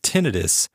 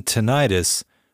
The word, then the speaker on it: tinnitus: Both tinnitus and tinnitus are correct. I know that can be very confusing, but both pronunciations are correct.